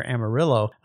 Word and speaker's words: Amarillo: He lived near Amarillo, where they did pronounce the two L's, even though that's a Spanish name for, I think, yellow.